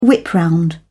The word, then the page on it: whip-round: British English